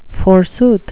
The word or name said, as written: forsooth